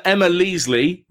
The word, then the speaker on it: Leasley: So Emma Leasley, rhymes with Weasley, says, waste of money, worst film I've ever seen.